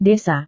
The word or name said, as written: desa